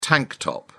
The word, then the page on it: tank top: British English